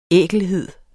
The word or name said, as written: ækelhed